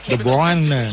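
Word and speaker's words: De Bruyne: ต้องออกเสียงถูกนะ The Bruyne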